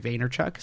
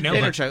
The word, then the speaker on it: Vaynerchuk: So, and using social media, you know, I'm going to butcher his name, but like Gary Vaynerchuk, is that his name? – Vaynerchuk. No, you Vaynerchuk.